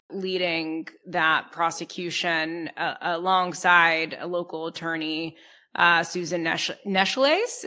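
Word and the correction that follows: Necheles: Is that how you say it? – Necklace. – Okay. Thank you, Roger. Apologies for mispronouncing the name.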